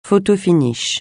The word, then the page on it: photo-finish: francais